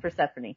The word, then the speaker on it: Persephone: He's like, it's Persephone.